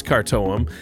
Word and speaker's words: Khartoum: I don't know if I'm saying that right. The band's name is spelled K-H-A-R-T-O-U-M, and I think it's Kartoum.